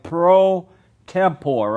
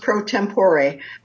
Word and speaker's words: pro tempore: So I want to ask you about, you're the president, if I'm pronouncing it right, Pro Tempore, right? – It's actually pronounced Pro Tempore, but that's okay.